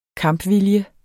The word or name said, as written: kampvilje